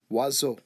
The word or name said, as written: oiseau